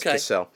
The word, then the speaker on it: Cosell: His name's Howard Cosell. It's pronounced Cosell.